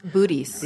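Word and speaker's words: Boötes: It's between the Hercules constellation and Bootes. Bootes, is that how that's pronounced? – Bootes.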